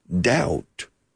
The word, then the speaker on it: doubt: It's a silent B, similar to the word duda, doubt.